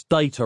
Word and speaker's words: data: "It's data, Roz, like potato."